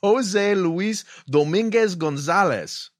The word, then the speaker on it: Jose Luis Dominguez Gonzalez: His name is Jose Luis Dominguez Gonzalez.